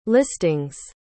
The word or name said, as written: listing's